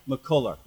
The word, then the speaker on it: McCullar: Chef Rhonda, how do we say your last name? – McCullar. – Okay. McCullar. Well, sure. I didn't know you gave it some extra. – No flair in it. Okay. It's just McCullar.